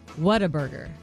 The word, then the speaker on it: Whataburger: and they said it is pronounced Whataburger. – Say it again. – Whataburger. Ah, keep it at you. They said it's Whataburger, not Whataburger. And some people say Whataburger instead of Whataburger.